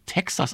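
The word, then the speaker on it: Tejas: Wir wollen noch ein bisschen Musik hören von ZZ Top und ich habe aufliegen die LP Teages, die glaube ich auch Texas ausgesprochen wird.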